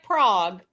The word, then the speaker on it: Prague: Spelled like Prague, but pronounced Prague, because this is the U.S. of A.